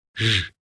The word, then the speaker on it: zs: In the Hungarian alphabet, this is one single letter and is pronounced Zs, resembling the middle of the English word treasure.